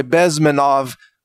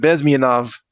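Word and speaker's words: Bezmenov: Bez, in English, you might say Bezmianov, but I believe in Russian you pronounce it Bezmianov.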